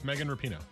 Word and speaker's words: Megan Rapinoe: Yeah, it's Megan Rapinoe.